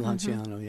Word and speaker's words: Lanciano: I mean, they showed the one miracle from, I'm probably going to mess up the pronunciation, but Lanciano. – Lanciano, yeah.